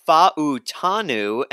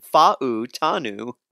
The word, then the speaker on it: Fautanu: You know, I went with Fontenot. I'm so sorry for butchering his name. – That's all right. It's Fautanu, and I know that because Joe Shad of the Palm Beach Post also mentioned him for the Miami Dolphins, and he has great pronunciation.